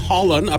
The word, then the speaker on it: Haaland: It's pronounced Haaland, apparently.